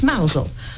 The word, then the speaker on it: Mousehole: Of course I do, with Mealy Mousehole. – His name is Neil, and Mousehole is pronounced Mousel.